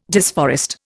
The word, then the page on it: disforest: Indian